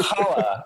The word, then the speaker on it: challah: It's pronounced challah. It's pronounced challah.